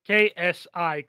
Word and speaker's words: Xi: Like KSI, C. Like KNS combined in one word.